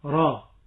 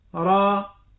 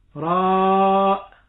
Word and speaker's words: را: والراء مثل ألف لام را، أقول را، بعض الناس نسمعهم وهم يقرؤون فيقولون راء وكأنه يضع همزة بعد الألف ويمد أكثر من حركتين. النطق الصحيح را،